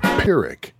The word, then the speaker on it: Pyrrhic: Is that how you pronounce that, Pyrrhic? – Give me a moment. Pyrrhic. – Pyrrhic. Oh, okay.